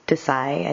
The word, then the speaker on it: Desai: And the Desai is Desai. I think that part is probably easy to pronounce, at least the way I am probably mispronouncing it. And the Idie, it's spelled H-I-D-I-E-R.